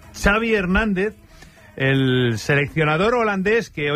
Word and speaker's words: Xavi Hernández: What is the correct pronunciation of your first and last name? – Xavi Hernández. – Xavi. – Okay. Yeah, Xavi like chocolate.